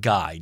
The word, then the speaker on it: Guy: It is spelled Guy, G-U-Y, but I am pretty certain it's pronounced Guy.